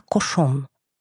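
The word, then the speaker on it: Cauchon: Фамилия епископа, который стал организатором и председателем инквизиционного процесса, над ней была «кошон». По иронии судьбы, она звучит так же, как французское слово «свинья», хотя иначе пишется.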